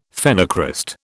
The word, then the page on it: phenocryst: British English